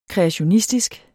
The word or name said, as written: kreationistisk